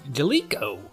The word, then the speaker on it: Jaleco: How do you say that? Is that Jaleco or Jaleco? – Jaleco. – Jaleco. I like that even better.